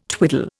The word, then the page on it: twiddle: Indian